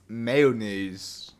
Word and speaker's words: mayonnaise: That how you say mayonnaise? I take you laughing at sentences mean I say it wrong. – It's mayonnaise. – Mayonnaise. One more time, please. – Mayonnaise. – Mayo.